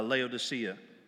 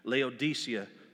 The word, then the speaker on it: Laodicea: This is this is um, and this is talking to the church of uh, Laodicea, Laodicea, excuse me. Laodicea is the proper pronunciation, but it's speaking to this church.